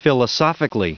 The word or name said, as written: philosophically